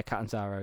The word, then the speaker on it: Catanzaro: It's Casey Catanzaro. – Yeah, Catanzaro, yeah.